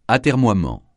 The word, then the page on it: atermoiement: francais